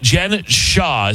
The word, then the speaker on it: Jen Shah: And Real Housewife Jen Shah, did I say it right? – Mm-hmm.